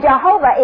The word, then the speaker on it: Jehovah: And Jehovah is peculiar that in the very middle of that name, it's Jehovah. It's a guttural sound when you pronounce it correctly.